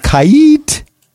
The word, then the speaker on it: Kait: I would like to tell you about Kait. – Kait. – That's apparently, I'm reading the pronunciation guide, and that's how you have to say it.